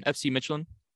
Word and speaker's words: Midtjylland: and I'm going to butcher the name, Midtjylland. – That was butchered. – My God. Give it to me. Midtjylland? – That was good. FC Midtjylland.